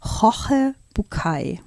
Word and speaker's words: Jorge Bucay: Also ich würde sagen, er heißt Jorge Bucay. – So würde ich ihn auch aussprechen, okay.